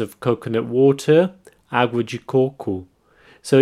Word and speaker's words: coco: This word is quite similar to the Portuguese word for coconut, coco. Now, here the emphasis is in the first syllable, coco, and the O at the end has an U sound, ku.